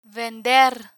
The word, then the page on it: vender: spanish